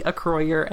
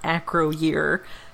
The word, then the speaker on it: Acroyear: it was always supposed to be Acroyer and not Acroyear, as it clearly is spelled.